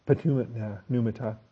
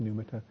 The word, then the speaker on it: pneumata: The polypresent prayer pneumata, the P is silent with pneumata.